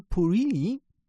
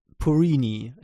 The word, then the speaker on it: Purini: Und da gibt es bei The Verge diese Woche ein schönes Interview mit dem Crunchyroll-Präsidenten Rahul Purini. Jetzt habe ich das schon japanisch ausgesprochen, aber Purini, ja, ganz normal.